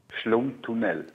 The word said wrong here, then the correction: Schlundtunnel: Schlumm. Wie schreibt man denn Schlund. – Das? Schlund. Wie schreibt man das? – S-C-H-L-U-N-D. – Ah, Schlundtunnel. Jetzt habe ich es verstanden.